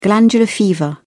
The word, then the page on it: glandular fever: British English